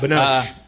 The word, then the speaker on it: Binoche: No, they scratch out your name and it says Doug Benson, and instead of Juliet Binoche, am I pronouncing that correctly? – Sure. – Yeah, Binoche.